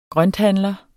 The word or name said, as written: grønthandler